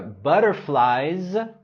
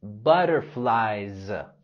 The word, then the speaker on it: butterflies: En la palabra butterflies, tenemos el sonido Z. Butterflies.